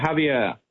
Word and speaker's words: Javier: Is that how you pronounce his name? – Yeah, Javier.